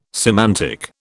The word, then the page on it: semantic: British English